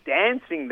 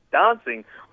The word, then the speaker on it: dancing: I also realise you said dancing there. I don't think that's the correct pronunciation, Damon. It's dancing.